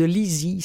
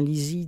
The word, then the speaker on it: Lisey: Mais ça, c'est l'histoire de Lizzie. Ça s'écrit L-I-S-E-Y, mais on dit bien Lizzie dans le livre audio.